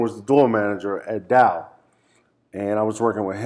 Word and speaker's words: Tao: And Dow, which most people pronounce Tao. – Whoops. – Yeah. T-A-O, it's pronounced Dow in the Venetian.